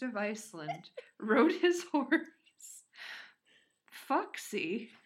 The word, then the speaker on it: Faxi: It's spelled F-A-X-I. So I'm really glad that I looked up how to pronounce it because I would have just been like, Faxy?